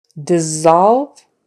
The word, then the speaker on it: dissolve: All right, so now on to the words that are not as frequent, and those are the SSs where you pronounce the SS as a Z. There's just a few common ones. Scissors, possess, dissolve, dessert.